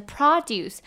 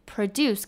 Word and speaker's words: produce: 那它还有一个另外一个意思呢，它的发音比较不一样，是 produce，它的 o 呢是发 r 的音，重音在前面。 produce 跟 produce 不太一样哦。